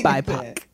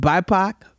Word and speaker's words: BIPOC: BIPOC. – Because I don't know how to say it. BIPOC.